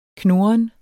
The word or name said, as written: knurren